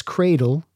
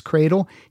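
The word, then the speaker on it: Kreidel: Travis Cradle, it's spelled like dreidel, but with a K. So I'm saying Travis Cradle.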